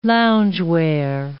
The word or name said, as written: loungewear